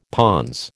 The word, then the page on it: pons: American English